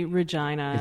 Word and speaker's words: Regina: It's actually Regina. – It's Regina. Yes. It's a mistake a lot of people make, I'm sure. – Yeah, it's some people call it the city that rhymes with fun.